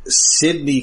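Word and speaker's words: Cydne: But this first aired November 29th, 2014, written by the writing pair of Stephen Granite and Sydney Clark. I think I'm pronouncing this correctly. It's C-Y-D-N-E. – Sydney? – Yeah, I guess it's probably Sydney. – That's what I thought, yes.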